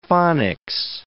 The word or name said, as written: phonics